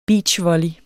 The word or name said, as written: beachvolley